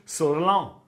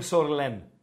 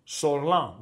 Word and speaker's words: Σορλάν: Σορλάν είναι, παιδιά. Δεν είναι Σορλέν. Σορλάν, έτσι ακριβώς. Σολάν διαβάζεται.